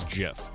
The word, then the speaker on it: GIF: It's either GIF or JIF. Mm-hmm. And apparently the correct pronunciation is JIF.